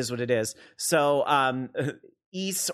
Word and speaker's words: Ys: By the way, there are a lot of people that think this is called WISE, because it's capital Y, lowercase s, and so there's a lot of people that call this series WISE. So it's Ys. – Yeah. So even I know that pronunciation.